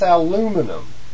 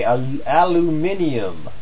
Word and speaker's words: aluminium: It's aluminum. And, or as some of my friends over there in Europe would say, aluminium. Yeah, well, however you want to pronounce it, nonetheless, it's not tin.